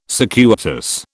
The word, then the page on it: circuitous: British English